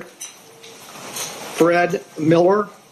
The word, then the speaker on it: Fred Miller: Fred Miller, F-R-E-D-M-I-L-L-E-R.